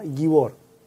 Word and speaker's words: gibor: Eh, y aquí una, aclaración, pastor, o sea, no aclaración, sino que una reafirmación que nos da eh, James Parker, dice que tú estabas pronunciando bien, correctamente, eh, la palabra gibor, porque el diccionario Strong dice que Ninrod rod" fue un gibor que está acentuado en la segunda sílaba, es decir, en la letra O. Así que estaba bien como lo habías pronunciado, que había quedado la duda ahí.